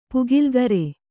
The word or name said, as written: ಪುಗಿಲ್ಗರೆ